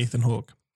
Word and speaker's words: Ethan: I've always pronounced his name Evan. – Evan? Evan Hawke. – Evan. – Most definitely Ethan Hawke.